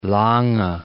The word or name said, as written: Lange